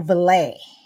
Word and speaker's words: valet: But he was most known as George Patton's personal valet. Valet is actually how you say it.